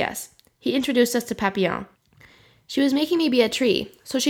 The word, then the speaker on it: Papillon: You pronounce it Papillon.